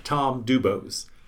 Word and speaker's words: Tom DuBos: Tom DuBos.